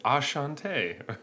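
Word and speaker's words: Enchanté: There's an accent and it's spelled weird. It actually it says Ashante.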